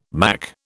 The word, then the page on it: mack: British English